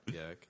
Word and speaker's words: Bierce: It's pronounced Bierce. – Yes, Ambrose Bierce.